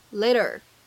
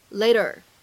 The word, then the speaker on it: later: later 这个单词，各位同学要注意一下，在美式发音当中呢，这里的字母 t 它是一个典型的 flap t，它是一个闪音的处理，所以呢在美式发音当中呢，我们读成 later。 later，然后呢，再来看一下最后这一处发音技巧，walking up，放在一起呢，可以做一个自然的连读。